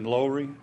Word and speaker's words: Lowry: Now, I've been told you pronounce his name Lowry, so we got to make sure we get that right.